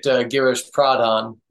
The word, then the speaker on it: Pradhan: Were you looking for something different, or Frontiers recommended this garish, I'm going to screw his last name up, Pradhan, am I saying that right? – Yeah, I'm Americanizing it a bit, Girish Pradhan, right.